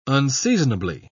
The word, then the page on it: unseasonably: British English